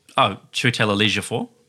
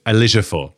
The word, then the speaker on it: Ejiofor: Andrew Scott, who plays C, allegedly replaced, I always mispronounce his name, Chiwetel Ejiofor. – Ejiofor.